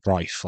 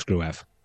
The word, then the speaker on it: Gruev: Well, to be honest, I was doing some looking after it, because after the recent games where Bryn Law has been pronouncing it Grife, like Trife, but with a G, I was looking into some old footage of Grife when he was in the Bundesliga, and the Bundesliga commentators pronounced it as Grove.